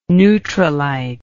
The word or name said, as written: Nutrilite